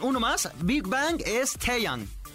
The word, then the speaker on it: Taeyang: Luego siempre me confundo como con la sílaba tónica porque podría ser Taeyang. – Taeyang, ajá, Taeyang. – Taeyang. Sí, pronuncias bien, Taeyang.